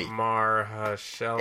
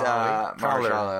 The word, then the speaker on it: Mahershala: Mahershala. – It's Mahershala Ali.